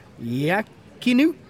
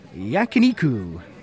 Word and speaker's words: Yakiniku: Yakiniku. – Yakiniku. – Yakiniku.